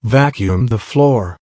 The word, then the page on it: vacuum the floor: American English